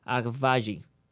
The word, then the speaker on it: Agbaji: Eh, me van a corregir aquí, perdón si pronuncié esto mal. Agbash, ah, ah, perdón, Agbaji. Por eso es que necesito a Sean aquí al lado el experto en nombres internacionales.